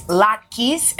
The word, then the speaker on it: latkes: One holiday dish that is always a crowd pleaser is latkes, and here to share her family recipe.